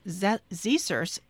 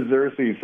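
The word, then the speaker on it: Xerces: He's the co-director of the Pollinator Conservation and Agricultural Biodiversity Program at the ZSERS Society for Invertebrate Conservation. Mace, good morning. I think I just butchered the name of your society. – You might have. It's the ZSERS Society, but it's great to be on, Amy.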